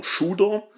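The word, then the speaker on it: Tsjuder: Und wir reden hier natürlich von Shooter. Geschrieben Zuder, ausgesprochen Shooter.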